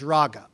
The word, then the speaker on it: raga: The Hebrew word, the way we would spell that, the sound of the Hebrew word in English is raga, R-A-G-A.